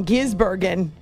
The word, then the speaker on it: Gisbergen: Shane Van Gisbergen. No, I think I said it wrong. Gisbergen. I put an extra N in there.